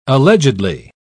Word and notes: allegedly: British English

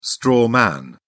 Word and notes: straw man: British English

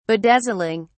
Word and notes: bedazzling: British English

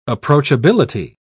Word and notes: approachability: British English

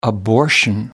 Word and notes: abortion: American English

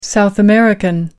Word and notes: south American: American English

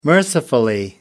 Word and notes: mercifully: British English

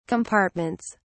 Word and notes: compartments: British English

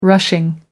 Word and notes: rushing: American English

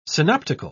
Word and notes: synoptical: British English

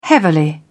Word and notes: heavily: British English

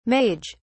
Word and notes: mage: British English

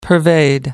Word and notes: pervade: American English

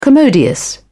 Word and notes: commodious: British English

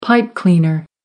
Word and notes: pipe cleaner: American English

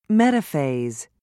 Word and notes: metaphase: British English

